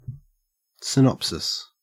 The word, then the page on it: synopsis: Australian English